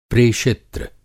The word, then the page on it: प्रेषित्र: Hindi